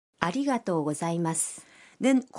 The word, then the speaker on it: ありがとうございます: ありがとうございま 아리가또 고자이마스는 고맙습니다라는 뜻의 감사의 표현입니다.